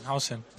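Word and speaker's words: Huijsen: Dean Hausen, Hausen, no sé cómo prefieres que te llamemos. – Hausen, Hausen, Hausen, Hausen, Hausen, Hausen.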